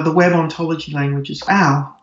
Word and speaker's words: OWL: And that's the web ontology language, WOL. It's pronounced OWL.